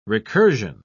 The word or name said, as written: recursion